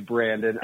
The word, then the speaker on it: Brandon: Just like Let's Go Brandon. Brandon. – But not exactly like Let's Go Brandon. – No, my name is Brandon.